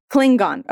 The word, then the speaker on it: Klingon: Am I saying that right? – Klingon? – Klingon. Okay, Klingon. Which I'm somewhat sheepish to admit ended with,